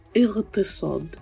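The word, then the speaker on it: иртисад (اقتصاد): Например, в слове «иктисад», которое означает «экономика», они произносят это слово как «иртисад». Не «иктисад», а «иртисад».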